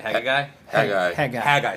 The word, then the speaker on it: Haggai: How do you say it? – Haggai. – Excuse me. Okay. Haggai. So two G's tripped me up.